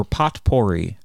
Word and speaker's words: potpourri: Well, let's move on to potpourri, or potpourri, I believe it's actually pronounced.